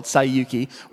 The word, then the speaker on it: Saiyuki: It was just called Saiyuki, which is the Japanese pronunciation of the original title for Journey to the West.